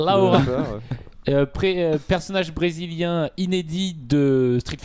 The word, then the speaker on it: Laura: Plutôt que de diffuser des images de Zangief, ils ont diffusé des images de Laura, puisque on va le prononcer comme ça. – On va dire Laura.